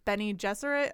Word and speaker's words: Bene Gesserit: And they actually ended up the models for the Bene Gesserit, I think that's how you say it, sisterhood in Dune. And Brian was explaining that's sort of why Gesserit sounds similar to Jesuit.